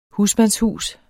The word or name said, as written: husmandshus